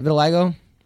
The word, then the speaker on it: vitiligo: Vitiligo? – Yep, vitiligo.